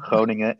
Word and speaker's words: Groningen: It's called Groningen. – And, Groningen. Uh, oh, no. Groningen. yeah, that's a good pronunciation.